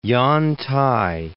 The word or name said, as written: Yantai